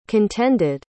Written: contended

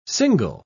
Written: single

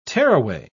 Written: tearaway

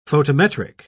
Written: photometric

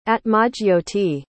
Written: atmajyoti

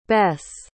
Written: besse

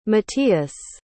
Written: mattias

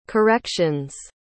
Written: corrections

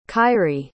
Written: ky'rie

